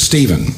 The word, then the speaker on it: Stephen: My middle name is Stephen, spelled like the first martyr of the church.